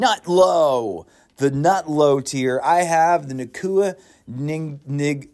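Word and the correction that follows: Njigba: The Nacua Njigbas.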